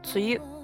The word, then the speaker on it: 梅雨: もうすぐ梅雨の時期が来るんですからね。大家今天怎么样呢？大阪今天是雨天，因为马上要到梅雨的季节了。梅雨写成梅子的梅，下雨的雨，读作梅雨，就是梅雨季节。